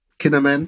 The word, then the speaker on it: Kinnaman: Du hast den Joel Kinnaman, wenn ich ihn jetzt richtig ausspreche. – Kinnaman, genau heißt er.